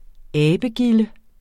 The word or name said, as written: abegilde